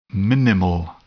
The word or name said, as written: minimal